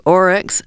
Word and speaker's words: Oryx and Crake: It sounds like Orcs and something, as in Tolkien, you know, so what's the exact title? – Let me spell it for you. Oryx, O-R-Y-X, and crake, C-R-A-K-E.